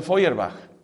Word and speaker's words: Feuerbach: De esa fe sí se puede decir lo que dijo este filósofo tan crítico del cristianismo, Luis, o sea, Ludwig Feuerbach, que se escribe Feuerbach, y se pronuncia Feuerbach.